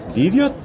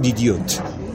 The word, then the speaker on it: DiDio: O ya ofrecí mi disculpa pública, porque no es Dan Didiot, es Dan Didiot.